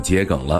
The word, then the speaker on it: 桔梗: 接下来就是去找桔梗了。这里科普一处读音小知识：桔梗的桔读作桔，而非菊，它是一种化痰止咳药。这种草药的花朵为紫色，而形状有点像五角星。 – 哦，那应该就是这个了，这个桔梗是吧？